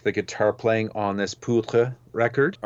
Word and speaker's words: Poutre: P-O-U-T-R-E.